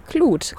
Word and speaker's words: Clouth: Sag nochmal, Knut oder Klut? – Klut. – Klut-Quartier. – Wird das so ausgesprochen? C-L-O-U-T-H und dann Quartier. Klut-Quartier.